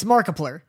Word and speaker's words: Markiplier: Markiplier? – Ooh, yeah. It's Markiplier. – Is it Markiplier? – It's Markiplier.